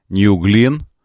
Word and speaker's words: New Glenn: Согласно трансляциям сайтов и Space, мегаракета New Glenn, правильно говорить, ну, в честь астронавта.